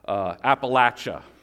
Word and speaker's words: Appalachia: So when we think about repentance and belief, I went to a little Bible college in the mountains of Kentucky, Appalachia. That's the correct pronunciation from everybody that lives there.